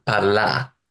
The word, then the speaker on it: parlar: Another, I think, quite distinctive feature of Catalan is that rather like English, well, rather like my accent of English, final R is not pronounced usually. Not post-vocalic R. It's not as widespread as that as it is in English. But for example, parlar, Parlar. It's just pronounced parlar.